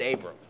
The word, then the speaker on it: Abram: You know, one of the most difficult things to do when you're preaching sermons is to read the name Abram and pronounce it Abram, right? It's like, by default, it's like Abraham.